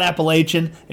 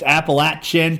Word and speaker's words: Appalachian: I had a lady from the heart of Appalachia tell me that, nope, it's not Appalachian, it's Appalachian, pointed to her chin, at chin.